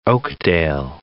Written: Oakdale

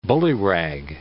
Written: bullyrag

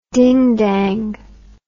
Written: dingdang

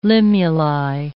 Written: limuli

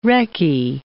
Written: recce

